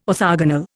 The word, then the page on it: orthogonal: Indian